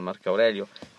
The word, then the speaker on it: Marco Aurelio: Pensiamo ai bronzi di Riace o addirittura arrivando al Marco Aurelio, questa volta l'ho detto bene, al Marco Aurelio alla fine del del percorso romano, diciamo il periodo della decadenza.